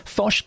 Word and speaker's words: Foch: So that's spelled F-O-C-H, but it's a Breton name, so it's pronounced Foch.